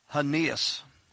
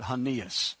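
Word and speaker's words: Aeneas: And there he found a certain man named, this is going to be kind of hard to pronounce, but I'll give it to you, Hanias. It doesn't look like it, but that's how you pronounce it, Hanias.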